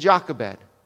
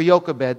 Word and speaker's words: Jochebed: And Jochebed, or Jochebed, depending on how you want to pronounce it.